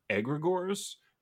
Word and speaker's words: egregore: I'm going to take a left turn here and ask you about something that I don't know a lot about, but you talk often about egregores, which I think is the proper pronunciation.